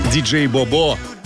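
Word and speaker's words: DJ BoBo: Диджей Бобо. Вот именно так произносится имя этого исполнитель. Ретро FM.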